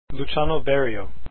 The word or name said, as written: Berio